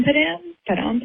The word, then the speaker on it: Padam Padam: Padam Padam? Padam Padam? I don't really actually know how to properly say it, but she pronounced it Padam Padam.